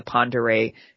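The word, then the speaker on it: Pend Oreille: It was carved out of Stevens County, and it contains many members of the Ponderay Native American tribe, which it, is also known as the Kalispell. – And bonus points for pronouncing that correctly, because it's not spelled that way.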